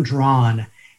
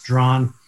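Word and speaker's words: Draughon: My name is Tom Drawn, and it's spelled funny. It's D-R-A-U-G-H-O-N. It looks like dragon or something, but it's just pronounced Drawn.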